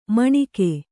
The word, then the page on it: ಮಣಗು: kannada